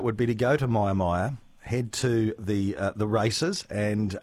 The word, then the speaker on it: Mia Mia: Myer Myer is spelled M-I-A-M-I-A.